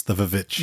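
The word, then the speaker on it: The VVitch: That's how that's pronounced, right? The Vavitch. Yeah. – Yes, The Vavitch.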